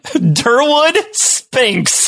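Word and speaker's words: Derwood Spinks: Durwood Sphinx. – How do you spell that? – It's the first name, D-E-R-W-O-O-D. Last name, S-P-I-N-K-S.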